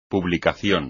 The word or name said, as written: publicación